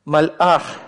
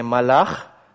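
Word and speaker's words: Мальах (מלאך): Обратите внимание на произношение этого слова. Это не малях. Малях – это он царствовал. Да? Малях, как вы помните, когда без алифа у нас. Малях. Малях – он царствовал. Но если мы добавляем сюда алиф, изменяется огласовка чуть-чуть. Мальах. Произношение будет другим уже, не малях, а мальах, да, из-за того, что вот этот вот алиф образует остановку, прерывает поток воздуха.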